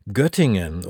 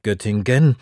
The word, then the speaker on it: Göttingen: Göttingen oder in Barbaras Aussprache Göttingen.